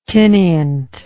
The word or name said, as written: tinnient